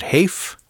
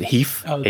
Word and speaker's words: HEIF: It's a, you said Haif? – Heif? Well, they pronounce it Heif. H-E-I-F. The high efficient image format.